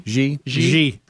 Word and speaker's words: Xi: Xi, is that how you say his name? Xi? – Xi.